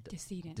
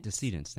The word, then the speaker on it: decedents: So today, we will discuss Leota's research, which involves multi-isotope analysis to aid with the identification of unidentified decedents. Did I pronounce that right? – Decedents. – Decedents, thank you.